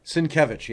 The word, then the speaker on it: Sienkiewicz: Sienkiewicz, yes.